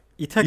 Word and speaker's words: İthaki: Gittim abi e, İtaki yayınlarından çıkan, böyle İtaki diye okunuyor değil mi? Yanlış bilmiyorum. – İtaki, İtaki, İtaki falan evet.